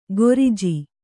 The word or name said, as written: ಗೊರಿಜಿ